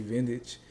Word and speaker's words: Ventage: So Smokey is spelled S-M-O-K-E-Y. Vintage is spelled V-E-N-T-A-G-E. So instead of vintage, like V-I-N-T-A-G-E. I just put a E there.